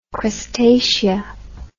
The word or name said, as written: crustacea